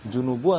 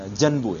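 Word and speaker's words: junubu (الجنب): junubu atau enggak, maksudnya bacanya junub ya, junubu ya, bukan janbu ya, bukan ya.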